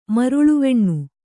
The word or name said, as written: ಮರುಳುವೆಣ್ಣು